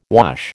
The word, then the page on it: wash: American English